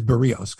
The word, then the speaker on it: Berríos: And it is Berríos because I've been listening to Dan Schulman say his name and I've got it, Mike.